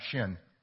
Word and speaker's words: Sin: When you look in your Bible, it's, it's spelled S I N, it's pronounced Shin.